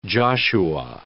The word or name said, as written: Joshua